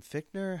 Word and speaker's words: Fichtner: I love, though, is it William Fichtner? How do you say his name? – Yeah.